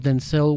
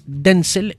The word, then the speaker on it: Denzel: La película está protagonizada por Denzel Washington, quien ayer me di cuenta que su nombre es Denzel y no Denzel. – Denzel Washington. – Sí, Denzel Washington le dijo a Jimmy Kimmel, que se llama, me dijo, mi nombre es Denzel, no Denzel. – El acento en la primera.